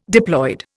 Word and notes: diploid: Indian

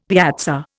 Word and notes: piazza: Indian

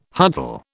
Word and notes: huddle: British English

